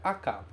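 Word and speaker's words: aka (赤): Ele se pronuncia aka, vermelho.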